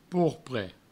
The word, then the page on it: porpre: pourpre; provençal